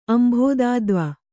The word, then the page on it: ಅಂಭೋದಾದ್ವ: kannada